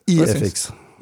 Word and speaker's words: Das EFX: Wird das eigentlich das EFX ausgesprochen oder DAS EFX? – EFX.